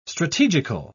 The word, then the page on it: strategical: British English